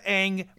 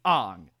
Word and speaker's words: Aang: Aang, they pronounce instead of Aang with a long A, it's a short A. It's Aang.